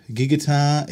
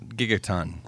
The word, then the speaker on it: Gigaton: Happy day, Gigaton. How do you say it? Gigaton? Gigaton? – Gigaton. Like a gigaton. – Yeah, it's just a ton.